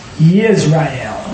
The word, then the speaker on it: Yisrael: And what I'm trying to say is, it's really Y-I-S-R-A-E-L, so it's pronounced Yisrael. It's always like put a Y in front of the I. Yisrael. Shall you send forth unto the war?